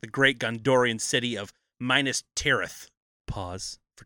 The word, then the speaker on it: Minas Tirith: Minas Tirith. – That's what I said, Minas Tirith. I keep saying minus instead of menus, but now I know it rhymes with penis, so we got it.